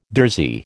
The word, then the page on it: durzi: American English